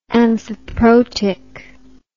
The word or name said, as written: amphiprotic